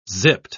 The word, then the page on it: zipped: British English